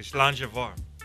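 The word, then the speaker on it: slàinte mhath: Now, in Scotland, we say slánge vár. – Oh, okay. – How's my pronunciation on that, Graham? – It's pretty good. You're getting better every week.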